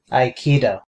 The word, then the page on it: aikido: /aɪˈkiːdəʊ/; /ai.ki.do/